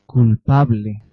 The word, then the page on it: guilty: American English; /ˈɡɪl.ti/